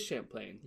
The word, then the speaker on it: Champlain: It is Champlain. – Yeah. – That's so weird. Why is it Champlain instead of Champlain? – Because it's not an English name. So it's pronounced differently.